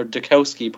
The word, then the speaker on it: Dukowski: So, and also a group I don't know, but it's what Chuck Dabowski's, or Dukowski, pardon me.